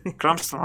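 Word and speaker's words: Cranston: Eh, es el de, en, una, un episodio que relata cuando este, está Luis y Hal, que es este Carl, este Brian Carlston, Carlston? Ah, no pronuncio bien, puedo. Cramston, ok.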